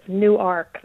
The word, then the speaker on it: Newark: It's spelled like the city in New Jersey, but you say it differently, right? – Correct, yes. It's Newark. That's how they say it here.